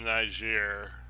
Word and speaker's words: Niger: So we got this military announcement from Niger. I'm not. – Thank you for pronouncing that properly and not getting us kicked off of everything.